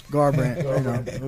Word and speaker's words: Garbrandt: Garbrandt.